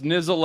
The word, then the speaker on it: Niziol: And the last tidbit, the official Polish pronunciation of my name is Nizio.